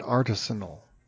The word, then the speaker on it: artisanal: It unfortunately overlaps a lot with artisan in people's minds. And so it usually gets pronounced artisanal.